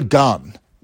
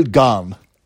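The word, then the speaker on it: Gane: It's Cyril Garn is how you're supposed to say it here. I don't want to be Sean. I don't want to Sean Green this name up, but it's Cyril Garn. If you're saying it properly here in the accent.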